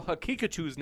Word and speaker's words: Hakikachu: By the way, Daniel Hakikachu's name is pronounced Hakikachu, like Pikachu with ha in the front.